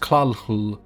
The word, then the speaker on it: Cthulhu: Similar to how Cthulhu is supposed to be pronounced Klalhul, Erliae has some rather weird alien pronunciations too.